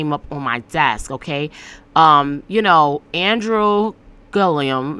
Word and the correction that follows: Gillum: make sure I'm saying the right word, okay? his name. Let me make sure I'm saying his name correctly. So Andrew Gillum,